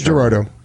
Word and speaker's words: Girardeau: Yeah, Girardeau. – Gonna lose the barn. – Girardeau? Is that how you're saying it? – Cape Girardeau. – Girardeau, yeah. I'll buy that.